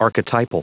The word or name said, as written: archetypal